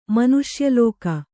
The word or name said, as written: ಮನುಷ್ಯಲೋಕ